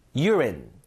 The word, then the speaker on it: urine: 자, 요거는 철자상으로는 유인데 읽을 때 유로 읽죠. urine, urine.